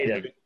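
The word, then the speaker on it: Cadeyrn: It's Caden Neville, mate. It's pretty simple. Caden. 2 syllables.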